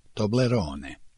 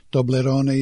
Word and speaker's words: Toblerone: Tobler se poigrao svojim imenom i nazivom Torone, duhovito ih spojivši u jednu reč, Toblerone. Toblerone je u nemačkom imenica ženskog roda, a izgovara se različito. – Toblerone. – Toblerone.